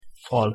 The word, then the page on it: fal: Hungarian